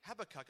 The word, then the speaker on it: Habakkuk: And actually, it is pronounced as you read it Habakkuk, apparently.